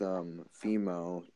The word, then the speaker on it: Vimeo: There's Vimeo. Not Vim. Gosh, I can't pronounce the name of the company. The Vimeo.